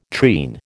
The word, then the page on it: treen: American English